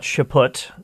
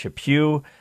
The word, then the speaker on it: Chaput: Not Chaput, as some people mispronounced his name. No, no, no. It's pronounced Chaput. He's one of the leading churchmen in the United States. Like, pew, pew, pew. That's how you pronounce it.